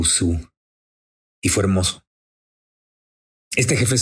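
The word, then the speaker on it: Sioux: Se escribe Siux, y mucha gente equivocadamente les dice Siux, pero la pronunciación correcta es Su.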